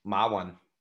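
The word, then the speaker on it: Ma Wen (马云): How do you say in Chinese? – Ma Wen. – Ma Wen. – Ma Wen. Yeah, that's his. Ma is his last name, which is horse, and his first name is Wind or Cloud.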